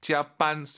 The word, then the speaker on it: 加班: 짜반 쌍비읍보다는 약간 비읍 느낌으로 힘 빼시고 짜반 하면 좋을 것 같아요.